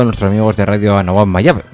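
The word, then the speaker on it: Anáhuac Mayab: Anahuac Mayab. – Yo creo que lo he dicho perfectamente. – En Mérida.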